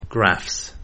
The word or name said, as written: Graphs